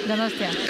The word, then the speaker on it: Donostia: How do I say it? Donostia. – Donostia.